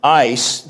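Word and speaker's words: eis (εἰς): And the Greek New Testament actually uses a preposition, eis, that's epsilon, iota, sigma. You can transliterate it as E-I-S, but pronounce it like ice.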